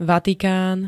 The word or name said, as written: Vatikán